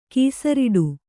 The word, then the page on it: ಕೊಸರಾಡು: Kannada